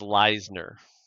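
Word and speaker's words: Leissner: And there he met married a young, determined college student named Kathy Lessner, who wanted to be a school teacher. Oh. – I think that's pronounced Leisner.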